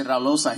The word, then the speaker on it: Raul Loza: I don't even think you say his name like that. You say Raul Loza.